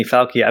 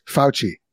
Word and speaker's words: Fauci: I will say this: if it's Italian, if he's Italian, which I think he is, then it is Fauci. That's how you pronounce the C. I or an E after a C in Italian is a ch sound.